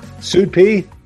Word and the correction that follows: SUDEP: S-U-D-E-P? – SUDEP. – SUDEP. – Sudden death with epilepsy. SUDEP. – SUDEP. Okay, got it.